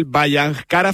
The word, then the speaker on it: Bhayangkara: Desde hace poquitas fechas, mister del Bhayangkara Fútbol Club, si lo digo bien, Óscar. – Eso es, sí,